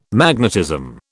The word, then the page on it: magnetism: British English